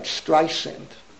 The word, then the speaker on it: Streisand: Look at Barbara, let me pronounce the name right, Streisand.